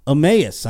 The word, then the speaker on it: Emmaus: Emmaus, I said it right. Thank you, Alex. – You nailed it.